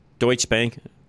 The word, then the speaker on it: Deutsche Bank: I think that's how you say it. Is that right? Deutsche Bank. Deutsche Bank. Okay, Deutsche Bank. It's German. I knew that.